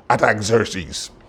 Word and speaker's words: Artaxerxes: It's a funny mouthful of word to pronounce, but his name is pronounced Artaxerxes.